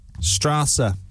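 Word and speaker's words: Straße: Straza, I almost said there. Might be a word for street or avenue. – German. – But am I saying it right? – Strasse. – Right.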